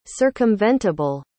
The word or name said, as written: circumventable